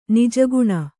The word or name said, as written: ನಿಜಗುಣ